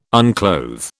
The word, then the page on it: unclothe: British English